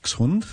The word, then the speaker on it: Dachshund: So the Germans would call it a duck. Dachshund. – Dachshund.